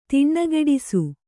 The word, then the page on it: ದನಿಗೂಡಿಸು: danigūḍisu